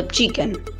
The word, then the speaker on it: Chkn: Hoy les vengo a recomendar un juego llamado Chicken. Se pronuncia así, pero se escribe C-H-K-N.